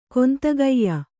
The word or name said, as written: ಕೊಂತಗೆಯ್ಯ